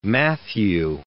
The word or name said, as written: Matthew